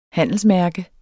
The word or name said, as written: handelsmærke